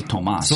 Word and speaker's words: Tomas: It'll become Thomas's ringtone. – Thomas Zelenkis. – That's actually Tomas. – Zelenkis? – Because there's no H.